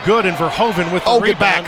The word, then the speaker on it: Verhoeven: But we're hearing they pronounce it Verhoeven now. The PA announcer has been corrected, so we'll make that correction as well.